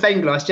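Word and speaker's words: Vengloš: How do you say his name? Go on, you pronounce it. – Dr. Joseph Venglos, yeah, you got it spot on.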